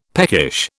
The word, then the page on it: peckish: British English